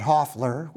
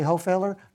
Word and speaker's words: Hofeller: It's spelled like Hofeller. It's German. But we pronounce it Hoffler, which makes it very confusing, because when people see it spelled, they say, "Oh, you're Jay Hofeller?" No, I'm Jay Hoffler.